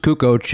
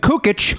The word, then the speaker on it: Kukoc: No, no, Kukoc is the way that it is. But people would go, "Yeah, so I'm watching the game last night and Kukic." Nope, they, he, he, you're talking to him. Hey, Toni Kukic. Oh, it's pronounced Kukoc.